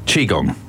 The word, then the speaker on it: Qigong: And how do you say that? Qigong? – Qigong. – Qigong. – Yeah, it's Qi. Qi is the life force.